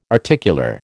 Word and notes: articular: American English

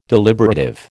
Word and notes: deliberative: American English

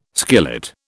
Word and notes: skillet: British English

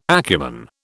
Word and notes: acumen: British English

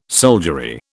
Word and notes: soldiery: British English

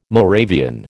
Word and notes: Moravian: American English

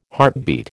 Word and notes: heartbeat: American English

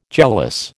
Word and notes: jealous: American English

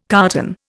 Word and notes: carton: Indian

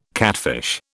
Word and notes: catfish: British English

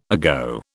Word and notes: ago: British English

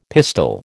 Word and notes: pistol: American English

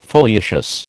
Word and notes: foliaceous: American English

